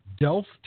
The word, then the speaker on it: Delft: And on this one, it is going to be in, I'm trying not to butcher this one, Delft, Netherlands? – That's right.